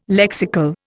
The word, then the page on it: lexical: Indian